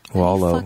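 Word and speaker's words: Coster-Waldau: Streaming with, yeah, Nikolai Coste-Waldau. – Waldo. Say his name. Waldo.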